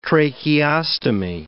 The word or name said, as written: tracheostomy